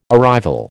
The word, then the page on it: arrival: American English